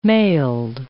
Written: mailed

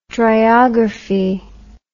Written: driography

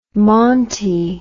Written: monty

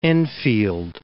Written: Enfield

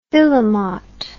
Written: filemot

